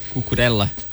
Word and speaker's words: Cucurella: Cara, será que pro Cucurella, Cucurella, como é que fala? – Cucurella.